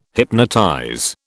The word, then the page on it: hypnotize: British English